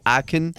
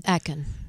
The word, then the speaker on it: Akon: Now, it's spelt like Akon, which you might confuse with like the rapper, but it's actually, you'll hear her say Akon. – Akon.